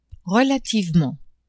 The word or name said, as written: relativement